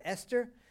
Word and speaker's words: Esther: And she said, just take the H away. And then Mark Hester came up. He said, say my last name, Hester. He said, now take the H away, Esther.